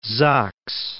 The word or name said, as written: Sachs